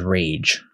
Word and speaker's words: Raige: Like, literally, his last name is Rage. – Yeah, it's spelled R-A-I-G-E.